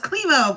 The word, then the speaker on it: Klemow: Klemow, but Klemow.